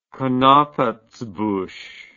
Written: knappertsbusch

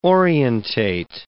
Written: orientate

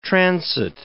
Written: transit

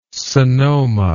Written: sonoma

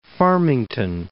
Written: Farmington

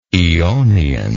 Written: eonian

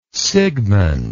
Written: sigmund

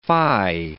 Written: phi

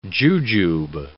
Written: jujube

